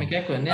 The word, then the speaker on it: McEachran: Yeah, McEachran.